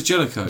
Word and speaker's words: Jellicle: I thought it was Jellicoe. – Jellicoe cats, but it's spelled like Jellicle.